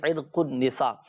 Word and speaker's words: عرق النساء: عرق النساء صحیح تلفظ ہے، عرق النساء،